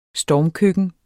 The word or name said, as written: stormkøkken